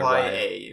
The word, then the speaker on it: Raya: R-A-Y-A. Yeah, Raya. If you're it's Raya. I just listened to it again. It's Raya.